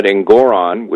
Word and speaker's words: Engoron: The First Amendment protects incorrect commercial speech, and yet Engoron, which rhymes with moron, found, you know, Trump guilty of that.